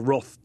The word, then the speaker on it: Wrath: And we're going to talk about Steinbeck later, but I've just been rereading The Grapes of Wrath or Roth, depending how you say it.